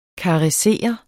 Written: KARESSERES

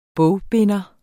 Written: bogbinder

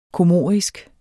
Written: comorisk

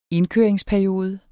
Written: indkøringsperiode

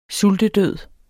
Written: sultedød

